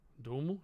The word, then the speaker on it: Duhamel: Duhamel, yeah.